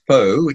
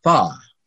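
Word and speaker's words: phở: It's not pronounced po, it's pronounced pa.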